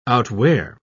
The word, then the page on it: outwear: British English